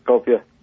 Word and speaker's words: Skopje: Skopje.